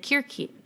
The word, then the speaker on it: Circe: She said Circe. – And then I looked up like the official like pronunciation of the goddess, and they said Kirki.